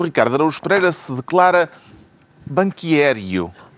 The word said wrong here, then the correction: banqueiro: É esta a pronúncia? Eh, sim, é, É? banqueiro, sim.